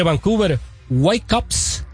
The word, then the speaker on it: Vancouver Whitecaps: Bueno, pues hoy martes el equipo de Vancouver Whitecaps. Lo pronuncié bien, discúlpame, porque ahora ya tengo que. – Se escuchó un poco francesado, pero pues como son de Canadá, se vale.